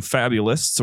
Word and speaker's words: Fabulist: We have a few Fabulists or Fabulists. I don't know. It's spelled like Fabulous. But I know it's not. I know it's supposed to be Fabulist because it's you're writing a fable, but okay, get on with it.